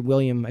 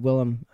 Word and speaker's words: Willem: I didn't say William, I said Willem.